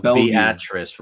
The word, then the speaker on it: Beatrice: It's pronounced Beatrice.